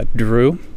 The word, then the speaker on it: Drew: O-N-D-I-N-E. What's your last name, Ondine? – Quinn. – Okay, and how about you? – Drew, D-R-E-W.